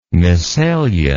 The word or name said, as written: messalian